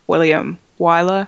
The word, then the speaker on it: William Wyler: And especially because, like, just in keeping with the Darren Aronofsky thing, I've kind of been a fan of William Wyler, mm-hmm.